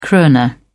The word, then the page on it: krona: British English